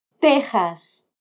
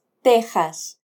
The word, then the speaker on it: Texas: Texas. – Se recomienda escribirlo con X y pronunciarlo con el sonido de J. Jas, Texas.